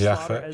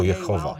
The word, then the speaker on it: Jahwe: Wiecie, kim jest Jahwe? Jest to imię, które niewłaściwie wymawiamy jako Jehowa.